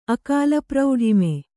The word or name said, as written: ಅಕಾಲಪ್ರೌಢಿಮೆ